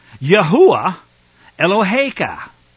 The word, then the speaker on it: Yahuwah Eloheka: you will return to Yahuwah Eloheka. Yahuwah Eloheka, not the Lord your God. Let's get his name right at least if we're going to return.